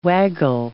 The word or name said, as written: waggle